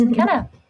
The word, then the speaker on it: Mykenna: So here's Mykenna.